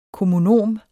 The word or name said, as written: kommunom